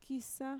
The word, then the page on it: kiisa: Mixtec